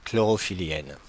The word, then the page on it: chlorophyllienne: Paris; [klɔ.ʁɔ.fi.liɛn]